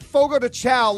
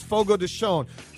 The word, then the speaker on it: Fogo de Chão: According to the restaurant who sent the correct pronunciation, it's not Fogo de Chão like we have all thought for many years. It's Fogo de Chão.